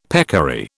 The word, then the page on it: peccary: British English